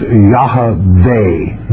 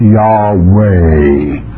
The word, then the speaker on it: Yahweh: it would be pronounced Yahweh, not Yahweh.